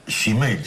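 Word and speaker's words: shemale: Semale. – Shimei, se dice. Shimei.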